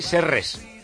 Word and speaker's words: Serres: Por aquí veía cerca de las fronteras con Bulgaria y con Macedonia, en una localidad que se llama, se pronuncia así, Serres.